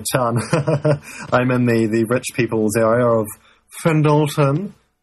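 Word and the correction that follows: Fendalton: Actually pronounced as Fendleton, but anyway.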